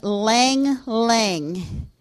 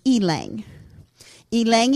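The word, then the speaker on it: ylang-ylang: Valerian oil and Lang Lang. It's spelled Y-L-A-N-G. Some people pronounce it Elang. I'm a Yankee. I say Lang Lang. But the proper pronunciation is Elang. Elang Elang and valerian.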